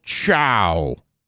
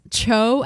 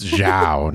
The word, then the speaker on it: Zhao: Han puffs himself up and tells the men to fall in, and they're going to go take down Admiral Zhao. I forgot to mention this. So he mispronounces Admiral Zhao's name wrong. – Yes, he does. – He also calls Sokka Soka. – Yes. Cho, Admiral Cho and Soka.